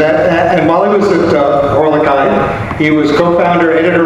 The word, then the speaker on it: Horlick: It's H-O-R-L-I-C-K.